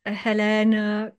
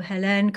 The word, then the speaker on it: Hélène: I'm on Instagram as Hélène Cardona Magic, which is my name is spelled two different ways.